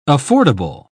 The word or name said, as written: affordable